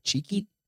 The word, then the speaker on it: Chiqui: Cheeky.